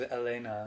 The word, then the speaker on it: Helena: And they don't pronounce the H, though. This is Elena.